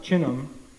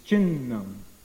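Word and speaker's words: chinnam (छिन्नम्): So when you have two consonants together, the same letter, then you have to actually pronounce both of them. It's not chinnam, it's chinnam.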